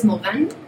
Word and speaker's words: Morin: Eh, en francés es Morin y nos conocemos como Morianos, Morán. Morianos.